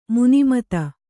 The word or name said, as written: ಮನುಮತ